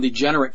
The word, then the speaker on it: DeGeneres: And lesbianism's introduced, and of course then we have Ellen DeGeneres comes on, and I said that correctly, by the way, not DeGeneres, I did say degenerate.